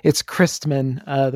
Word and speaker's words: Kreisman: It's Kreisman, the first syllable.